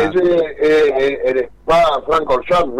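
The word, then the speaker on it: Spa-Francorchamps: El Spa Francorchamps, ¿no? – Sí, señor.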